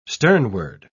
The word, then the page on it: sternward: British English